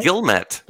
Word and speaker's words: Guillemette: How do you say your last name? Is it Guillemette? – Guillemette. – Guillemette. Guillemette. – Yeah. – So Guillemette. It's not French.